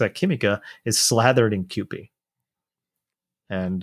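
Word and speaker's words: Kewpie: Oh, did I pronounce it wrong? – QP. Yeah. – I said Q-pie. Sorry, I was wrong.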